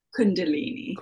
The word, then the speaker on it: Kundalini: Kundalini.